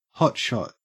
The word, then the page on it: hotshot: Australian English